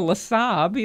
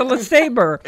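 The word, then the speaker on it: LeSabre: You don't say I'm driving a LeSab. You say it's a LeSaber.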